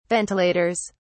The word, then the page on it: ventilators: British English